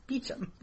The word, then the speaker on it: Beauchamp: No, regardless of how you see it spelled, it's pronounced Beauchamp.